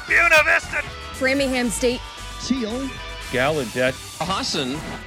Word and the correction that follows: Husson: Husson versus Alfred State and Brockport versus Alfred, we mentioned.